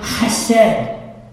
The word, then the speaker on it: chesed: The one word that you need to memorize in the original Hebrew is the word chesed. Okay? It's spelled with an H or a C, but it's H or it's C-H or just H-E-S-E-D. Chesed.